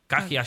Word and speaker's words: Kahaja: hotel, który się nazywa Kahaja przez Y, no to, a to się okazuje, że to się jakoś bardziej... – Kahaja. – Kahaja się wymawia. – No. – Czy Kaja niektórzy wymawiali? – No, coś w tych okolicach.